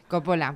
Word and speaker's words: Coppola: Va a venir a decirnos cómo se dice. Coppola. – Vale, pues Coppola. – Coppola, porque me ha señalado a mí, o sea que sí.